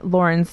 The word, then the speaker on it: Loryn: You can find me on social media, mostly Facebook, Instagram, and Twitter at Loryn Stone. I'm sure L-O-R-Y-N,